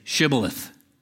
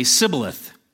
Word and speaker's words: Shibboleth: He would have him say, "Shibboleth." The Ephraimite could not pronounce it correctly, and he would say, "Sibboleth."